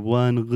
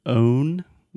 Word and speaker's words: own: So, when you said own, the things that you own, I almost heard on, on. And this is something I hear a lot with Brazilians.